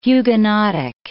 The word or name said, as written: Huguenotic